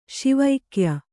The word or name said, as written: ಶಿವೈಕ್ಯ